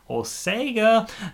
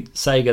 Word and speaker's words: Sega: Is that the right way to say it? Sega? – Oh, you thought saying Sega was wrong? – Yeah. – No, that's how... – For me, it's Sega. – No, that's how we say it here. Ooh, in Down Under. – Do not like that. I'm leaving. I'm going back to the UK. – I don't like when they say Sega. – Yes, because it is Sega.